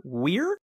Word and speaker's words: Weir: Best Director as Peter Weir.